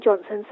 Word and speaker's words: Johnson: So my website is Dion Johnson. So my Dion is spelled D-I-O-N. Johnson is J-O-H-N-S-O-N.